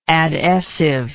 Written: adessive

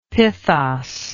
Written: pithos

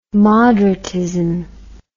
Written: moderatism

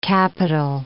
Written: Capitol

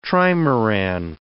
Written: trimaran

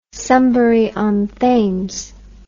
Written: Sunbury-on-Thames

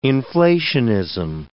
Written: inflationism

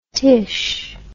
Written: tish

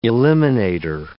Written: eliminator

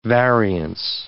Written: variance

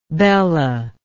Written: bella

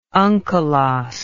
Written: unkelos

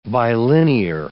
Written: bilinear

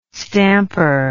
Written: stamper

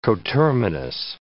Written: coterminous

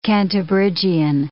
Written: Cantabrigian

